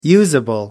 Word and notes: usable: American English

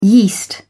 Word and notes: yeast: British English

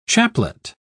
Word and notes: chaplet: British English

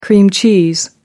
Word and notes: cream cheese: American English

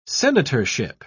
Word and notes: senatorship: British English

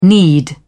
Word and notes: need: British English

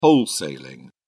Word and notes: wholesaling: British English

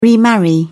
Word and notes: remarry: British English

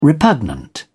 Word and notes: repugnant: British English

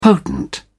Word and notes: potent: British English

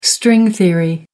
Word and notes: string theory: American English

